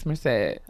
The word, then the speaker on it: Merced: It's Merced.